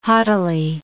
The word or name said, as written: haughtily